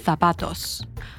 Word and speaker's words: zapatos: And a special note on the Spanish accent, the Z and the soft C are pronounced with a th sound. So zapatos would be pronounced zapatos, or canción would be canción.